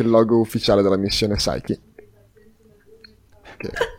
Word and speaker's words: Psyche: Psyche! – Non si dice neanche la P.